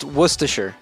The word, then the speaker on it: Worcestershire: It's Worcestershire. that's literally the town. The word we were just talking about. It's pronounced Worcestershire.